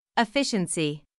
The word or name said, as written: efficiency